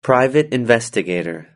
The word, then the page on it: private investigator: British English